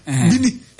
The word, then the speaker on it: Bini: di bo ba ba esanka aye B I N I I. By way, B I N I. Bini. Is it Bini? Oh, come on. – Yes, sir. French foreign pronunciation, eh, Bini.